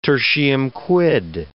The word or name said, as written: tertium quid